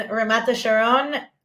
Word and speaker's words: Ramat Hasharon: And you played for, I want to say the name right, Kiryat Gat and then Ramat Hasharon. – Close. Yes, Kiryat Gat, you did that one good. And Ramat Hasharon.